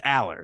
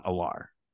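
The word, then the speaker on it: Allar: It's Aller, it's not Alar.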